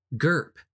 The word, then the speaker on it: Gerph: The artist name is GERPH, spelled GERPH.